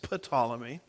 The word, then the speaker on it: Ptolemy: Now, up to this point in time, I've been pronouncing Ptolemy as Ptolemy. And my wife the other day says, you know that the P is silent there. And so I said, well, you tell me this now after I've been mispronouncing it for a month, but I think it's pronounced Ptolemy.